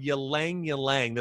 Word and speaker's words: ylang ylang: Another, actually probably my favorite oil for uplifting the mood, it's called ylang ylang. That's spelled Y-L-A-N-G, Y-L-A-N-G. Okay, it's two, ylang ylang.